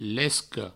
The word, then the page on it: lesca: provençal; lesco